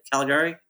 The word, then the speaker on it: Calgary: Calgary? – Yep.